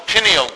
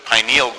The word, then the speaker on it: pineal: Slide 17 is a view of what is called the pineal gland, or as some people pronounce it, pineal gland.